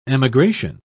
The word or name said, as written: emigration